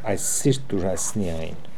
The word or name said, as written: Aiessirittoorassnian